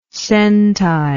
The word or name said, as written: centile